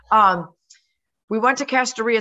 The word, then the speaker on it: Kastoria: So uh, Kastoria is how you say that one.